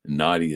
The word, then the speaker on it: Nauti: Nauti is spelled N-A-U-T-I as in nautical.